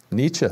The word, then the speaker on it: Nietzsche: How do you say Nietzsche? How do you say it? – Nietzsche. – There you go. You heard it, ladies and gentlemen. That's the official pronunciation of Friedrich's last name going forward.